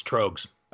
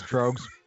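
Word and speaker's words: Tröegs: It's Trogues. – Oh, okay. Yeah, it's Trogues. Yep. – Oh, Trogues.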